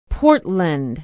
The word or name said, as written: portland